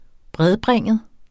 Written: bredbringet

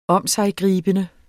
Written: omsiggribende

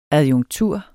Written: adjunktur

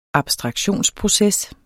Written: abstraktionsproces

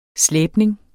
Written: slæbning

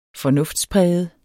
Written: fornuftspræget

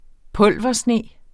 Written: pulversne

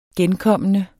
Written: genkommende

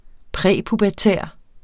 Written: præpubertær

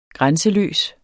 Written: grænseløs

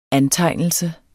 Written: antegnelse

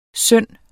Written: synd

